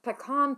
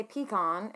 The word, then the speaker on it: pecan: I am always with pecan pie. I'm always like, some people say pecan and some people say pecan.